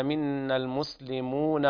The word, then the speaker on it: منا المسلمون: يلاحظ هنا الألف التي بعد النون في منا المسلمون لا تنطق حال الوصل لالتقاء الساكنين،